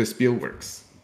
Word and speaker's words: Spielworks: as well as their relationship to Spielworks.